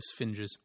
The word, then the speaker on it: sphinges: Well, there's like two sphinxes, so... – Sphinges? – Oh, yeah. Pronunciation guide. Got to get these cards right. We're a professional outfit here. They're sphinges.